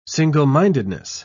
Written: single-mindedness

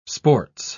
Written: sports

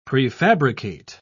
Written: prefabricate

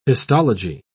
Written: histology

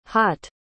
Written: haught

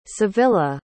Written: savilla

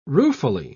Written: ruefully

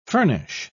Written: furnish

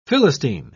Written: philistine

